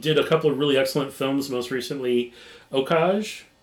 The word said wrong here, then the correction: Okja: Okja? – Okja.